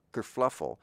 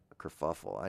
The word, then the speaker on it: kerfuffle: I guess it was a small kerfluffle about kerfuffle. I know I said it wrong on purpose.